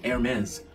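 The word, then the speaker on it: Hermès: hãng này nó không đọc chữ hờ nó đọc là Hermes Hermes Hermes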